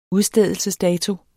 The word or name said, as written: udstedelsesdato